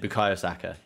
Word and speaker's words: Bukayo Saka: Bukayo Saka.